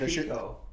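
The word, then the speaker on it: pico de gallo: Prosciutto wrapped asparagus, buffalo chicken dip, calamari, and pinco de gallo with chips. Jordan went last. He had pico. – What did I say? – Pinco. – Oh, sorry. Pico.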